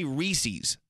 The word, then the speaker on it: Reese's: People say Reese's. – No. – Lisa says that. – Who says that? – My wife, Lisa, calls it a Reese's. I'm like, it doesn't rhyme with feces.